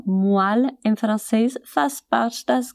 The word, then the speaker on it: moelle: Moelle se escreve M-O-E-L-L-E. E sim, as letras E e O juntas produzem o som de UA.